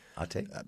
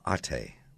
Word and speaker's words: Ate: About Ate. Did I say that right? Ate? – That's how I say it. – Ate, yes.